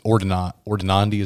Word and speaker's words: ordinandi: But as a kind of new priest, my first time trying this out, if there's like a lineup of guys, ordinandi, is that how you say it, right? – Right.